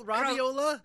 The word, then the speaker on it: Roviola: Is that pronounced right? Roviola? – Roviola. – Roviola. Roviola. Okay, I got to try to roll my R's too a lot.